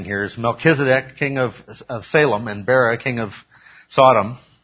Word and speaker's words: Salem: And just so it's clear, Salem, which was later named Jerusalem, Salem is how we pronounce it in English. The Hebrew is more in the nature of shalom, okay, meaning peace.